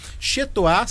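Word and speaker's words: chez toi: Chetoar se escreve CHEZ e Toar se escreve TOI.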